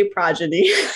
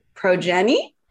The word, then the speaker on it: progeny: I knew I was saying it wrong, but I I was like, I read it and I'd be like, pro pro Jenny pro, and then he said, I was like, oh, the O is extended.